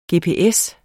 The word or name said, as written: gps